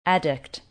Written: Adduct

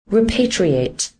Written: Repatriate

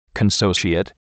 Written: Consociate

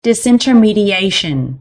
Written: Confect

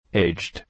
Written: Aged

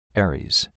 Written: Ares

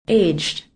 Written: Aged

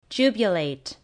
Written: jubilate

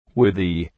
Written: Withy

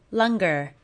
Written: Lunger

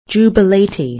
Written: Jubilate

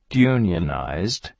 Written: Unionized

